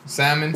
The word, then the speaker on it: salmon: Fuck everybody that pronounces the L in salmon.